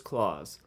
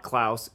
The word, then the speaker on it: Klaus: He is just Claws, not Klaus, even though that is how the movie title is spelled.